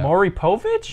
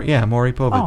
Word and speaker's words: Maury Povich: Maury Povich? – There we go.